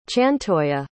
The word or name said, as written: chantoya